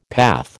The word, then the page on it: path: American English